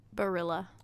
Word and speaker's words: Barilla: It's Barilla. – It's not pronounced with the Y Mm-mm. sound? – No, because that's Spanish, not Italian.